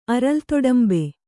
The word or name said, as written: ಅರಲ್ತೊಡಂಬೆ